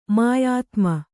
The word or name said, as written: ಮಾಯಾತ್ಮ